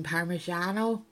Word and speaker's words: parmigiano: I've also made aubergine parmigiano. Is that how you pronounce it? Very good.